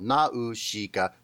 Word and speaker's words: Nausicaa: But there was a song released with the movie that like broke it down by syllable. Nausicaa. So I'll just choose that.